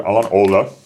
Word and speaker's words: Alan Alda: On to vyslovuje Alan Alda. Alan Alda.